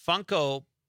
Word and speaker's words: Funko: Yeah, Funko Pop of the Dude.